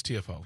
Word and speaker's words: Tiafoe: is it Tiafoe? Is that how you pronounce it? – Francis Tiafoe.